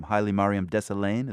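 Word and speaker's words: Hailemariam Desalegn: Now, apparently the deputy prime minister, I'm not even sure how to pronounce his name, Haile Mariam Desalegn, is that it? – You're right.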